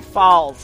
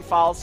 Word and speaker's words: Falls: Demetrius Flanagan Falls sound like falls. So that's actually one whole name because in the pronunciation guide, there's no space. So it's Demetrius Flanagan Falls sound like falls.